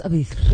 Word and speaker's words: Ibiza: It wasn't terrible, but except that it's pronounced Ibiza.